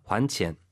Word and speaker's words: 还钱: 그래서 欠债还钱 이때 이제 그 환이 발음 유의하시기 바랍니다. 还钱 돈을 갚다죠.